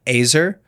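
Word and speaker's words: ezer: It is spelled E Z E R. I'm just going to take a shot at that. Oh, uh, pronunciation, it says here. It, it looks like Azer, Azer is the word for helper.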